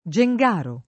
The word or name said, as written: Gengaro